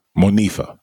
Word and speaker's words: Monifa: Monifa.